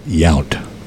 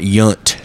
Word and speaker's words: Yount: Outfielders, you had It's pronounced Yount. – I call it Yount.